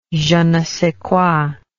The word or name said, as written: je ne sais quoi